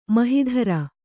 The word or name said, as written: ಮಹೀಧ್ರ